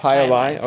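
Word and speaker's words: jai alai: I guess it was Hyalai. – Hyalai. – Oh, is that how it's pronounced? – Yes, Hyalai.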